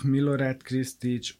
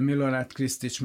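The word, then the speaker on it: Krstić: Tehát például Milorád Krisztics-et, bár nagyon nehéz kiejteni a nevét, mert úgy írja, hogy KRSTIC, tehát van ott 6 betű, és abból egy darab magánhangzó.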